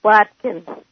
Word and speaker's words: Wachner: How do you pronounce it, by the way? – Watkin. It's a German name.